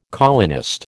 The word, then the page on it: colonist: American English